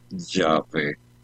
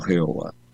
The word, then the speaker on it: Yahvé: lo más probable es que el nombre de Dios debería pronunciarse Yahvé, no Jehová.